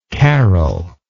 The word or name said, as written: caroling